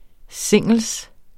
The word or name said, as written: singels